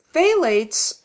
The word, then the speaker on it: phthalates: PH, which is silent. PHTHALATES. And the PH is silent, so it's phthalates.